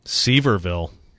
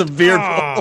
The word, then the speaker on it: Sevierville: He'd throw that sucker to Sevierville. Did I say that right? – No, you didn't. – Sevierville. God dang it. – Sevierville. – Oh, I said Sevierville.